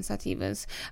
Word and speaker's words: sativas: Just like a little, like, they have like a whole, like, cannabis, balanced hybrids, indicas, sativas, I always say that wrong, sativas, edibles, concentrate, shatter, butter, wax, hash, and there's like totally a whole, like, accessory section, so everything, up to 50% off.